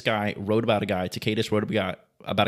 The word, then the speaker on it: Tacitus: It's Tacitus is how I pronounce it. Some people pronounce it differently, but Tacitus, he connected Jesus Christ when he was, I mean, sentenced to hang on a cross from Pontius Pilate.